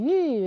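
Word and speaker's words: yu (玉): If you have a straw there, we pronounce yu, repeat yu.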